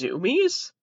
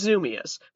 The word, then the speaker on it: Zumiez: And I'm just now learning it's pronounced Zoomies, like what animals do when they run around and people post it to Reddit. It's Zoomias.